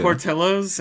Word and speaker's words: Portillo's: Chad's saying it's not pronounced Portillo's. Why does it have a double L then? – Get him. Get their Portillo's. Ass. Cool. – Is it Portillo's? Oh, okay. – Yes, yeah, it is.